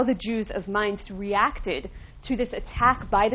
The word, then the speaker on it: Mainz: Mayence is the Hebrew pronunciation of the German city of Mainz. Mainz, M-A-I-N-Z, in its contemporary spelling, which was one of the well-established communities of Germany, of German Jews at that point.